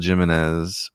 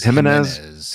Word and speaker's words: Jimenez: This hard-to-find 1 in 50 Phil Jimenez variant for her first solo Marvel series sells raw. – Say his name is Jimenez. – Jimenez. Yeah.